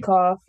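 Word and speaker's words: Keoghan: Barry Keough. – Yeah, it's probably Cough. – I want to just say it's pronounced like cough.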